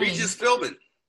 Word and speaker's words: Philbin: Regis Philbin.